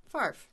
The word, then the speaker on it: Favre: Correct pronunciation. – Favre. – Brett Favre. – Brett Favre. Favre.